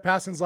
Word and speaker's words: Passan: Yeah, you can tell how exciting this is, because Vince just pronounced Jeff Passan's last name right for the first time in history, in the history of this podcast.